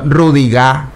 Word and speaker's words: Rüdiger: Lo metió Julian Nagelsmann por Schlotterbeck, que es uno de los centrales de Alemania, a Antonio Rüdiger.